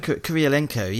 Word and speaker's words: Kurylenko: Do we have keeping it relevant to something later in the podcast, if I can pronounce her name probably, isn't it Olga Kurylenko? – Kurylenko, yes.